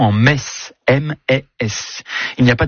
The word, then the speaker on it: Metz: Ayons une pensée ce matin pour les cousines de Metz en France, les mal prononcées, Gérard Mer et Chamonix.